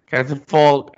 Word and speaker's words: catafalque: Actually, the correct pronunciation is cactus fork. Catafalque. – Apa hal? This is Prem's word. – Yep. – Catafalque. Well, obviously, C-A-T-A is cata.